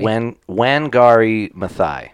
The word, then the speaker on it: Wangari Maathai: Wangari Maathai.